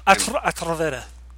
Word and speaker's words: Atrevida: Did I pronounce it right? – Atrevida. – Roll that R. You actually did better than me. – Atrevida.